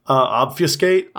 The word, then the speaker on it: obfuscate: But we still obfuscate. How do you say that word? – Obfuscate? – Obfuscate.